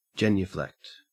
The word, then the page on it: genuflect: Australian English